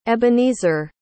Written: ebenezer

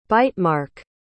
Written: bite-mark

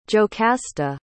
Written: jocasta

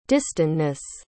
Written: distantness

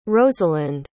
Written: rosalind

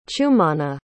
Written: chu'mana

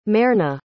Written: merna

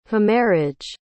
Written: hemaraj

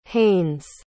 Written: haynes

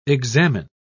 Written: examine

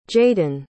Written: jayden

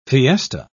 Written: fiesta

